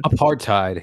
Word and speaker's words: apartheid: Apartheid, yeah.